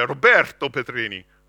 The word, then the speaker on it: Roberto Petrini: My best friend, I mean, you've got to understand, my full name is Roberto Petrini, right? And if you say it like an Italian, you say Roberto Petrini, right? You've got to roll those R's.